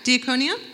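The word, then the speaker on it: diaconia (διακονία): Diaconia, is that the, how do you pronounce that? Yeah, diaconia, there we go.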